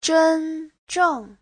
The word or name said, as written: zhen1zheng4